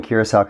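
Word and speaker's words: Curaçao: So I'm glad you pronounced the island Curacao, because I would have never known how to pronounce it.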